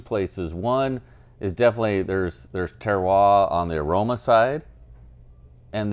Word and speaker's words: terroir: Like terroir, right? – Yeah. – How do you pronounce it? – Terroir.